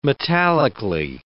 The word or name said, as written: metallically